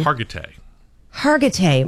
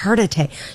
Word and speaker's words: Hargitay: How do you say her name? – Hargitay. – Mariska. Yes. Hargitay.